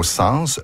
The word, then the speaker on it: sens: En français moderne, l'S final du mot « sens » se prononce toujours, sauf dans les expressions « sans dessus-dessous » et « sans devant-derrière ». Évidemment, il serait maladroit de prononcer « sens dessus-dessous » et « sens devant-derrière ».